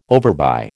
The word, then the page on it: overbuy: American English